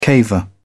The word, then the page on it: caver: British English